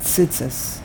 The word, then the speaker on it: tzitzis: That's why many people, they're going to say tzitzis. Tzitzis. Because the first tzadi in the word tzitzis is not followed by a yud. And if it's not followed by a yud, so then you pronounce it like a shva. Tse, the second tzadi is followed by a yud. So there you emphasize, it becomes tzi.